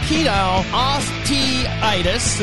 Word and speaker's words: pedal osteitis: On this week's show, we're going to talk about the pedal osteitis. Did I say that right? – Pedal osteitis.